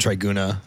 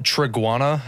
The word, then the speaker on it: Triguna: I've known that you were in the band Triguna. Is that how you say that? Or is there like a certain way to say that? – No, it's you're actually one of the few people that's pronounced it correctly. – How do people normally pronounce it? – People pronounce it Triguana, and I don't know how they get the second A, but...